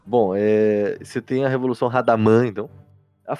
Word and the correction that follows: Ramadã: falei errado de novo. Ramadã. Ramadã, nossa.